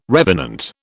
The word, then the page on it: revenant: British English